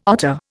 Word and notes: otter: Indian

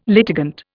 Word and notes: litigant: Indian

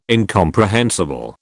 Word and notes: incomprehensible: British English

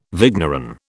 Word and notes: vigneron: British English

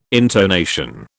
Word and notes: intonation: British English

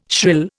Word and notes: shrill: Indian

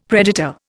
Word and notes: predator: Indian

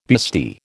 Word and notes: bustee: American English

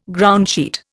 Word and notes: groundsheet: Indian

